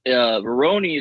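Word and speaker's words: Rurouni: What's the Veroni, or how do you say that? – It's Veroni. You said it right.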